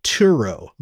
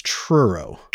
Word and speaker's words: Truro: Their first gig was at the Truro. On June 27, 1970. I keep pronouncing it Turo, but it's not. It's Truro. – Truro. – Like a churro.